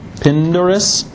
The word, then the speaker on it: Pindarus: By the way, how are you going to pronounce that guy's name? – Pindarus? – Yeah, perfect iambic pentameter.